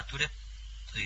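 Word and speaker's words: トゥレㇷ゚: だからトゥレップという発音になります。トゥレップ、最後ちっちゃいプが入るというね。 – ちっちゃいプはどういうふうに発音したらいいですかね。 – トゥレップ。 – トゥレップ。 – はい、そうですそうです。あのちっちゃいプなんで、あの口を閉じるだけみたいな。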